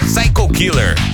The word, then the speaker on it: Psycho Killer: Cyker Killer, descer. – Tá rolando, cara. Talking Heads. O nome da música é Psycho Killer. Psycho Killer. Se você quiser pronunciar direitinho, é